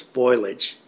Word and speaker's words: foliage: Before we redo minute three at 2:25, let's just review again foliage, spelled F-O-L-I-A-G-E. I'm drawing attention to that word because a lot of people pronounce it as foliage. It is actually foliage.